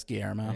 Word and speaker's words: Guillermo: I'm not bringing it up a lot, but that's Guillermo. – It's Guillermo. – Yeah, I like when you mispronounce Spanish words.